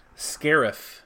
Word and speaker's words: Scarif: Scarif. C-A-R-I-F.